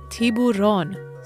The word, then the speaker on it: tiburón: Shark in Spanish is tiburón.